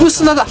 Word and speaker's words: 産砂: 産砂だ、そうだ、産道じゃなくて産砂って読むんですね、